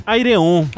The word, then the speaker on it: Ayreon: A-Y-R-E-O-N. – Aireon.